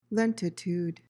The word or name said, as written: lentitude